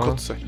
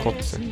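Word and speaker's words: Koze: Är det så man uttalar hans namn? Ja. Kotze. Ja, jag skulle säga Kotze.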